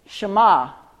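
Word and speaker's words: Shammah: Next, Jesse presented Shema, that's how it's pronounced, I believe.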